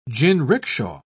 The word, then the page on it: jinricksha: British English